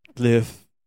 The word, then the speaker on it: Louvre: Why is Louvre spelled with an R? – It's French. That's Louvre? What they do. Yeah, that's what they do. – It should be spelled L-O-O-V-E.